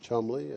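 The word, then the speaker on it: Cholmondeley: Chalmondeley pronounced Chumley and Blount pronounced Blunt.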